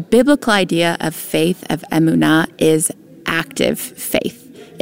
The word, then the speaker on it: emunah: Yes, the next word we're going to talk about, we mentioned already, it's the word emunah, or emunah, more properly pronounced in Hebrew.